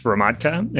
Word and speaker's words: Hromadka: My first name is T-Y-L-E-R and my last name is H-R-O-M-A-D-K-A, and that's pronounced Romadka, and that's Czech. And if you have any other questions about my last name, reach out on LinkedIn. – It's Czech and it rhymes with vodka.